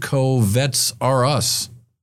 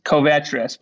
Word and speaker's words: Covetrus: Covets are us. Is that is not how you pronounce it, though, is it? – No, it's Covetrus.